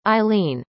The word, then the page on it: eileen: American English